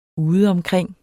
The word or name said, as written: udeomkring